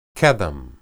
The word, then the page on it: qedem: hebrew